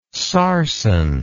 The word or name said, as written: sarsen